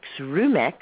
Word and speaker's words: Rumex: R-U-M, like rum, E-X, like a has-been. – Oh, Rumex. – Okay. – R-U-M-E-X.